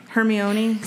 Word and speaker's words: Hermione: So many people didn't know how to say Hermione when those books first came out. – Yeah, I thought that was Hermione. – Hermione. – See? – Yeah. – See? – Which sounds like a pasta.